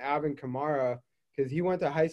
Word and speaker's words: Kamara: I was on a phone call yesterday with someone else whose first name is Kamara, and she pronounces Kamara. Mm-hmm. But on the phonetics chart, when I'm announcing the Saints, it's Kamara. – Kamara. – And she wanted me to remind him that it's Kamara.